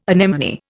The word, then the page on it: anemone: Indian